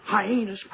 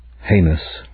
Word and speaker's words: heinous: Gambini, commit this hyenas crime. – As did the pronunciation of the word hyenas for heinous or heinous.